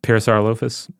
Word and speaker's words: Parasaurolophus: How do you say that one? – Parasaurolophus. – Okay. – That's the way my son says it. And anyway, he's usually better at dinosaur name pronunciation than me.